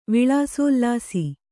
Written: ವಿಳಾಸೋಲ್ಲಾಸಿ

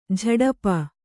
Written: ಝಡಪ

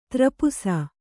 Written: ದ್ರಪ್ಸ

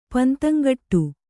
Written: ಪಂತಂಗಟ್ಟು

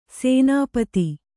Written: ಸೇನಾಪತಿ

